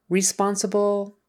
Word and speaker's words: responsible: Responsible. Could probably get away with that. Responsible. The accent is on the second syllable.